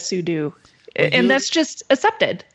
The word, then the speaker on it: sudo: But the co-inventor, Robert Cogshall, says it's meant for super user do. You should say sudo. So in this case, Axon ruled sudo. Sudo is the proper pronunciation. We've just all been saying it wrong.